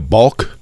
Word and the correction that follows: balk: Balk. – I go a balk. – Balk? – I believe it's a silent L.